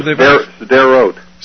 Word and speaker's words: Sderot: Oh, Sderot.